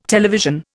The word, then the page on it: television: Indian